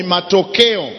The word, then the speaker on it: Matokeo: How do you say it? What did you say? Matokeo. – Matokeo. – Matoko. – Matokeo. – Matokeo. – Keo.